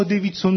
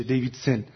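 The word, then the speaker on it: Davidson: یا دیویدسون، فیلسوف بزرگ آمریکایی، همه ما دیویدسون میشناسیم اما دیویدسون تلفظ نمیشه، دیویدسن.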